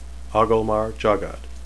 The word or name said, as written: agelmar